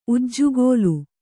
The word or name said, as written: ಉಜ್ಜುಗೋಲು